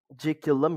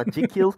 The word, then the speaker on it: Jekyll: Hayır demiyorum ama Jekyll yani sanırım Jekyll diye okunuyormuş.